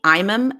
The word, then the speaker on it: Imam: my Twitter is my first name, Donna, D-O-N-N-A. My last name, Imam. It's spelled I-M-A-M.